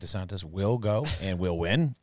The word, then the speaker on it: DeSantis: Ron DeSantis. – Dang. He said it kind of differently. – DeSantis. – He almost got it right. Usually it's Ron DeSantis.